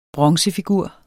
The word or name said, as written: bronzefigur